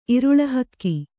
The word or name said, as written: ಇರುಳು ಹಕ್ಕಿ